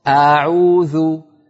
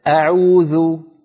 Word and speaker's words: أعوذ: أختي الهمزة في أعوذ لا تمطيها زيادة، اسمعيني سأنطق الخطأ، أعوذ أعوذ، هذا المط الزائد لا يصح، قولي هكذا أعوذ أعوذ أعوذ.